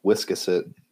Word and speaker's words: Wiscasset: It's pronounced Wiscasset.